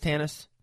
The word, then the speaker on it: Tanis: Tanis, am I saying that right first off? T-A-N-I-S, Tanis? – You are, Tanis. Yep.